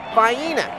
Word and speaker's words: Vienna: There's a town in Georgia that's spelled Vienna, but it's pronounced Vienna.